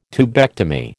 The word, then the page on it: tubectomy: American English